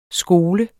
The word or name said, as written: skole